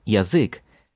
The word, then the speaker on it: язык: But when you say tongue, язык, it's the full Я at the beginning again. Язык. Язык. Язык.